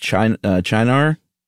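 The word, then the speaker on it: Cynar: I saw it pronounced Chinar.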